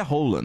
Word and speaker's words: Haaland: A pronúncia oficial do nome dele é Rola, né? – É, então, eu fiquei sabendo, fiquei totalmente constrangida.